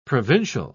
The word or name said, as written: provincial